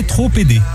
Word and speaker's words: trop aidé: On n'est jamais trop aidé. – Oula. – Trop aidé. Ah là, avec bien sûr la liaison bien prononcée.